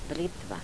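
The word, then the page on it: britva: dialetto croato di Lukezi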